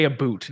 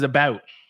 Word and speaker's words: about: First of all, Canadians don't actually say a boot. That's one of the biggest myths about Canada. And maybe like west, they say a boot. How I would pronounce it is about. Which is not a boot.